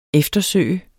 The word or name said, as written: eftersøge